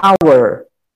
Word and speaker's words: hour: Mas tem algumas palavras no inglês que elas começam com o H, mas o H não tem som. O som vai ser iniciado na primeira vogal. Por exemplo, a palavra hora, em inglês, ela começa com H. Só que a pronúncia dela é hour.